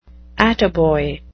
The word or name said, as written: attaboy